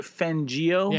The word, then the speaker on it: Fangio: Vic Fangio? – Yeah.